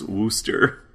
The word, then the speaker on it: Worcester: I think Worcester is pronounced Wooster.